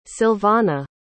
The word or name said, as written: Silvana